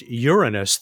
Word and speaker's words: Uranus: You know, my old astronomy professor, he did say that it's pronounced Uranus.